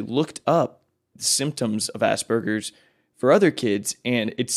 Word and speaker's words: Asperger's: Okay, that he has Asperger's, right? That's how you pronounce it. – Mm-hmm.